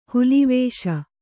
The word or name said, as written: ಹುಲಿವೇಷ